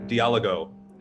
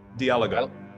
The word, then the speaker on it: Diálogo: As I've sort of been, you know, learning more about the word, I think Diálogo is probably the pronunciation I'm going to go with. So Diálogo.